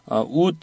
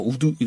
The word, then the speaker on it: would: would 이렇게 발음하시는 거를 좀 여러분들이 버릇을 들이시길 바라겠습니다. – would. – 왜냐면 네. d로 끝나는 단어는 받침발음. – 네, 받침발음. – 꼭 뭐 우드 이러지 마세요.